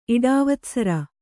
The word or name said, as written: ಇಡಾವತ್ಸರ